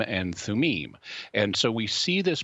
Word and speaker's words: Thummim: In the Old Testament, the high priest had a couple of objects that were known as the Urim and Thummim, or as Americans say, the Urim and Thummim.